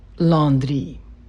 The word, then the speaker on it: Landry: My last name, Landry, is French-Canadian. In French, it is pronounced Landry.